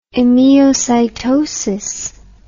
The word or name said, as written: emiocytosis